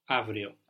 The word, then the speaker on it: αύριο: Αύριο. – Αύριο. This av is spelled AU. We've seen AU can make an AF sound, like in AFTO, AFTO, SAFTI, or an AV sound, like in AVRIO.